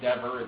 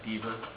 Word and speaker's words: Dever: Mark Dever, it's pronounced Dever, it looks like Deaver, he has a little book called The Gospel and Personal Evangelism.